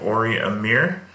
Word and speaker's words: Ori Amir: All right, so today in Mongo's House, I have Ori Amir. Did I say that right? – Yeah. You said it perfectly.